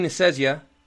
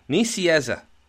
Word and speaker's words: Nicieza: It's so easy, too. It's just like it's spelled. Nicieza. – Nicieza.